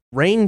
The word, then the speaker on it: Rian: It's the guy who directed Looper, Rian Johnson, or Rian Johnson, but it's spelled kind of like Rain, but with the I and the A swapped. So I think it's Rian, but I don't know. – I think Rian sounds about right.